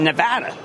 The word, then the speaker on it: Nevada: They've got the lowest cost, and they're also in Nevada. See, I learned how to pronounce that over the weekend.